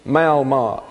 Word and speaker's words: malmad: It is a derivative of the word lamad, and it is malmad, M-A-L-M-A-D, malmad. It's actually malmad, sort of a short A sound.